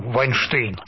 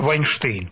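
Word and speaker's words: Weinstein: Ну, понимаешь, по-русски вообще эта фамилия, в принципе, это фамилия, ну, Вайнштейн. Как это вот в русском языке произносится, это Вайнштейн, понимаешь? – Ну, пусть будет Вайнштейн. Это легче. – Вот, да, потому что, да, ну, Вайнстейн это такое, ну, в английском такое произношение, да, то есть, в принципе, не очень это, в общем-то, и принципиальный вопрос, но дело-то не в этом.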